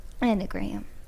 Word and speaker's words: Enneagram: An Enneagram. – Yeah. Enneagram. That's how you say it.